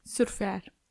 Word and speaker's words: surfear: So surf, it's the same, surf, but they pronounce the R, surf. And then to turn it into a verb, they've done AR, surfear. So you could say, estoy surfeando, I'm surfing.